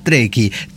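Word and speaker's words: trekkie: Pira, por favor, se pronuncia treki.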